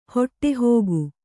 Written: ಹೊಟ್ಟೆಹೋಗು

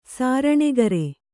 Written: ಸಾರಣೆಗರೆ

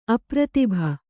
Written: ಅಪ್ರತಿಭ

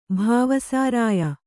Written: ಭಾವಸಾರಾಯ

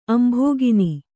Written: ಅಂಭಃಕಣ

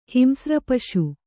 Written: ಹಿಂಸ್ರಪಶು

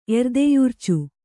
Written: ಎರ್ದೆಯುರ್ಚು

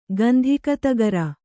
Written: ಗಂಧಿಕತಗರ

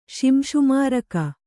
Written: ಶಿಂಶುಮಾರಕ

